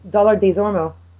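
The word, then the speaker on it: Dollard-Des Ormeaux: Dollard Desormeaux.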